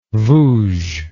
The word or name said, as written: vouge